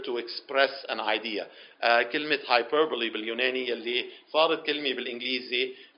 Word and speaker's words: hyperbole: H-Y-P-E-R-B-O-L-E with the E at the at the end pronounced like it's a double E, hyperbole.